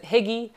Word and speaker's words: hygge: It's spelled H-Y-G-G-E. You'd probably want to pronounce it hygge. It's pronounced hygge.